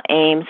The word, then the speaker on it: Eames: Michelle Eames, and that's spelled E-A-M-E-S, like the Eames chair.